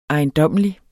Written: ejendommelig

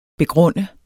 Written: begrunde

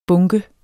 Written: bunker

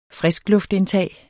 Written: friskluftindtag

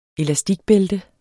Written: elastikbælte

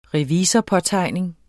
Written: revisorpåtegning

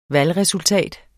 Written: VALGRESULTAT

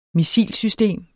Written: missilsystem

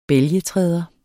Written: bælgetræder

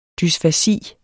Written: dysfasi

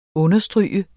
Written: understryge

